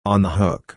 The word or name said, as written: on the hook